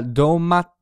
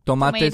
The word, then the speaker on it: domates: Domates. – Right. Bir tane domates. – Yeah, in Anatolia, some people say domat.